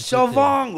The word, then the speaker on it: Siobhan: Sobón, güey. Neta, güey. Como Franchute. – Todo, sí, güey. Suena como Franchute, pero se escribe Siobhan, Sí, sí, de por sí hablar, oírlos actualmente es un pedo.